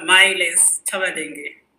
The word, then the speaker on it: Mailess Chabalenge: My name is Mailess Chabalenge.